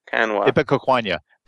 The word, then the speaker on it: Ipecacuanha: Ipecacuanha. The NH is pronounced like the NA in Spanish.